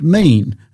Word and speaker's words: min: It's min, M-I-N, but it's pronounced mean, and it's found 17 times in the book of Genesis.